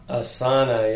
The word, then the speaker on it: Asana: So moving on here, we have the next one, which is Asana. – Asana, yes.